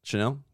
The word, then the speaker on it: Chanel: So like, obviously the channel is number one. Chanel. – There we go.